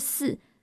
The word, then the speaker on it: 肆: 不过他在准备要注册社群平台的账号的时候，总觉得这个名字真的有点太俗气了，所以思考了一下，决定换成读音同样是" 四"，但是是" 放肆" 的" 四"。